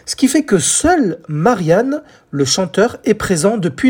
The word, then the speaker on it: Marian: Alors quand je dis Marianne, hein, ce n'est pas un prénom féminin, ça s'écrit M-A-R-I-A-N, sans le N-E à la fin. Mais ça se prononce Marianne.